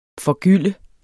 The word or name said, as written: forgylde